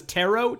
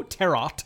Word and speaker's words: tarot: Pronounces tarot, tarot. – Tarot. The T is not silent. What are we, French? – Tarot.